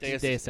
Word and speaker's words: Deus Ex: Deus Ex, is that how I pronounce it? – Yeah.